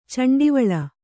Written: ಚಂಡಿವಾಳ

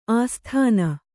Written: ಆಸ್ಥಾನ

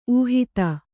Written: ಊಹಿತ

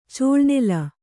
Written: ಚೌಳುನೆಲ

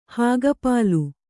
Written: ಹಾಗಪಾಲು

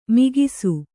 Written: ಮೊಗಸು

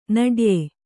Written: ನಡ್ಯೆ

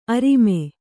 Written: ಅರಮ

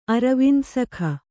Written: ಅರವಿಂದಸಖ

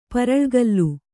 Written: ಪರಳ್ಗಲ್ಲು

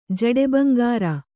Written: ಜಡೆಬಂಗಾರ

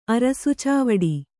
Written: ಅರಸುಚಾವಡಿ

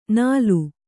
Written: ನಾಲು

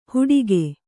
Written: ಹುಡಿಕೆ

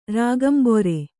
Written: ರಾಗಂಬೊರೆ

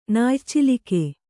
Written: ನಾಯಚಿಲಕೆ

